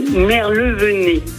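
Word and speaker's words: Merlevenez: Comment on prononce votre commune? Je ne voudrais pas faire d'impair. – Merlevenet. Voilà, c'est ça.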